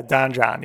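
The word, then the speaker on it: Don Jon: Did I even say it right? – Yeah, Don John. Yeah, that's right.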